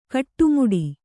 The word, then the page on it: ಕಟ್ಟುಮುಡಿ: kaṭṭumuḍi